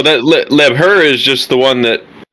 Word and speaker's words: Liebherr: It's Liebherr.